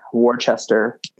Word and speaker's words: Worcester: Yeah, well, Gloucester has the same thing that Worcester has, which is like people would want to kind of throw in the ch. So Gloucester, Worcester.